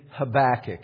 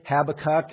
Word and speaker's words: Habakkuk: For those of you in looking for it in your Bible, Habakkuk, acknowledging that in other parts of the world they pronounce his name differently. Habakkuk is how some will pronounce it. I was teaching in another place and they were laughing at me for how I was pronouncing Habakkuk. And I just laughed right back at them and said, "You're the ones who have it wrong here."